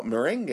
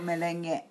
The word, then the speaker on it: merengue: You don't pronounce the gay at the end. – Really? – Because that's gay. – That's another Japanese English lesson. We call it merengue.